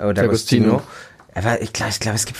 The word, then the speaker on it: D'Agostino: Gigi da, D'Agostino.